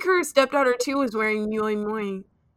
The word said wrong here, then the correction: Miu Miu: And I was just like, or Miu Miu, is that how you pronounce yeah.